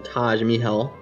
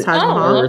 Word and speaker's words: Taj Mahal: Oh, I thought it was Taj Mahal. – Okay. It's more like a hard D, a Dodge Mahal. Okay. Yeah. Anyway, that's what she says. I don't know. I'm going to call it Taj Mahal.